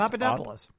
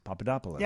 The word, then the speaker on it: Papadopoulos: There's an OU in there, but that's Papadopoulos. – Papadopoulos.